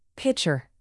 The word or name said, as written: pitcher